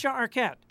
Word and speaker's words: Arquette: Somewhere Patricia Arquette has sensed that you have pronounced her name correctly.